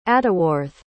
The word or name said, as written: atteworthe